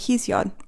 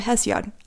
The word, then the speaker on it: Hesiod: Today, we're going to review the Five Ages, extracted from a poem called Works and Days by a Greek poet named Hesiod, or Hesiod, as there are two different pronunciations.